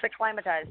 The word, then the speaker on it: acclimatized: Because it's not acclimated or anything. It's acclimatized.